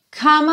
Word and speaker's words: come: Então, lembra aqui de juntar o come no on, porque o E do come não é pronunciado. Então, você nunca diz come me, e sim come, come. Ou seja, já junta esse M no on, come on.